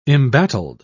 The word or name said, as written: embattled